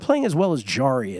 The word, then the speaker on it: Jarry: Nicolas Jarry, he's a Chilean player. Nicolas Jarry, it's spelled J-A-R-R-Y.